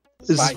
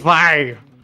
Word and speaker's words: Zwei: Deixa eu ver qual a pronúncia. – Zwei. Zwei. – É isso aí, tá certo.